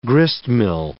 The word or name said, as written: gristmill